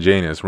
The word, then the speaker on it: Janus: And your name's fucking Janus, rhymes with anus.